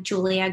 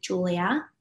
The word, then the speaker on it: Giulia: But my Instagram is I am Giulia Guerrieri. My name is spelled with a G actually. Mm-hmm. And TikTok is Manifest with Giulia, G-I-U-L-I-A.